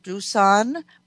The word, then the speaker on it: Doosan: I'm here with Clifton Doll from Doosan Bobcat in North Dakota.